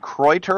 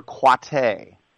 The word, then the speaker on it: Kreuter: Is it Kreuter or Quate? – No, you got it. It's Kreuter. It is Kreuter. Kreuter. Although Quate sounds more fancy, but it is Kreuter, yes.